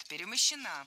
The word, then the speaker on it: перемощена: Перемощена – это неверно. Правильный вариант – перемощена. Ударение в этой форме падает на окончание «а» – перемощена.